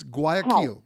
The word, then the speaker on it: Guayaquil: Well, I'm going in November to, I'm probably pronouncing it wrong, to Guayaquil or Guayaquil in Ecuador. – Okay, I'm going to tell you how to pronounce it. I'm going to tell you how to pronounce it. It's Guayaquil. Oh.